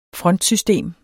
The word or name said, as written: frontsystem